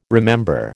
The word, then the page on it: remember: American English